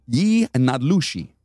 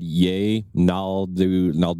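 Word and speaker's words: yee naaldlooshii: And I actually looked up the pronunciation for that, and I got that shit right. – That's actually how you say it? – Yi Nadlushi. – I would have said Ye Naldushai. – Right. That's not it.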